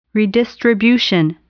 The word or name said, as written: redistribution